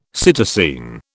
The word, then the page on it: psittacine: British English